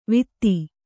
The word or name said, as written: ವೀಥಿ